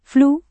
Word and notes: flu: indonesian

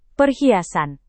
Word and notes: perhiasan: Indonesian

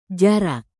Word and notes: jarak: Indonesian

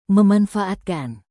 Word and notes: memanfaatkan: Indonesian

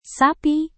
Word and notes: sapi: indonesian

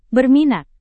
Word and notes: berminat: Indonesian

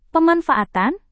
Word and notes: pemanfaatan: Indonesian

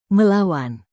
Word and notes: melawan: Indonesian

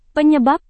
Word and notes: penyebab: indonesian

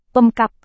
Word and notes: pemkab: Indonesian

